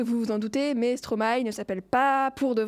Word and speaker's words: Stromae: D'ailleurs, oui, avant, avant je le prononçais Stromae, comme beaucoup de monde. Mais du coup, comme j'ai regardé plein de, plein de vidéos aussi pour me documenter, et lui prononce ça Stromae. Ah oui Du coup, ben je, le prononce euh, à la, je le prononce à la Stromae.